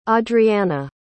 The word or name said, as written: audriana